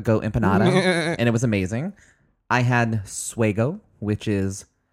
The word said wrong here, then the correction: sugo: Is it pronounced suego? – Yeah. No sugo, thanks. Sugo. It was pig cheek ragu.